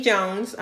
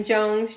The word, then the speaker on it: Jones: So my personal, information, my Facebook is Jenny Jones.